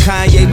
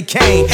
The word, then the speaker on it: Kanye: It's Kanye, but some of my plastics still say Kane.